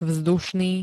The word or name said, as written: vzdušný